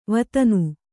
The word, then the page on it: ವತನ: Kannada